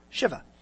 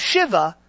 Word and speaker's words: Shiva: Am I wrong or did I say that right? Shiva, right? Shiva. Not Shiva, Shiva.